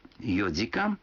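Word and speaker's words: йодзикан (四時間): Некоторые цифры в этих случаях произносятся не так, как обычно. 4 часа — это йодзикан, 7 часов — сичидзикан, а 9 часов — кудзикан.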